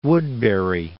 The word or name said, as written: Woodbury